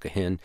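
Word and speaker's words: guzheng: It's spelled G-U-Z-H-E-N. It looks like it's goose hen or something. It's pronounced gehen.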